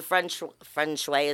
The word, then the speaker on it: feng shui: Do the feng shui.